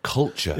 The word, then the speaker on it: Culcheth: Well, I grew, I grew up in a place called Culcheth. Culture. Yeah, would you like me to spell that? C U L C H E T H.